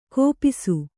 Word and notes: ಕೋಪಿಸು: kōpisu

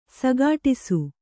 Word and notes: ಸಗಾಢಿಸು: sagāḍhisu

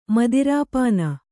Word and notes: ಮದಿರಾಪಾನ: madirāpāna

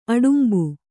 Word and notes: ಅಡಂಬೆ: aḍambe

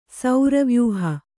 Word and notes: ಸೌರವ್ಯೂಹ: Kannada

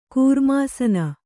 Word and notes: ಕರ್ಮಸಾನ: kannada

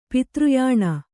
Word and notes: ಪಿತೃಯಾನ: pitř yāna

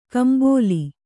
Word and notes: ಕಂಬಲಿ: Kannada

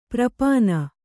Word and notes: ಪ್ರಾಪಣ: prāpaṇa

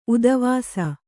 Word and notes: ಉದವಾಸ: kannada

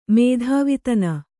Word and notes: ಮೇಧಾವಿತನ: mēdhāvitana

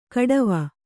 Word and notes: ಖಟ್ವ: khaṭva

